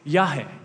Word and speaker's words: Yahweh: And because there's no vowels in Hebrew, there's different thoughts around the world as to exactly how to pronounce this. But one way is Yahweh, Yahweh, Yahweh.